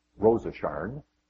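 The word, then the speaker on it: Rosasharn: In this novel, there's a young woman whose name is literally Rose of Sharon, biblical name, but in the pronunciation in her family, she's Rosa Sharn.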